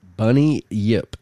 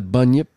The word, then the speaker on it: Bunyip: and according to Bunny Yip, just the way you spell it, B-U-N-Y-I-P, Bunyip, or maybe Bunyip, not Bunny Yip.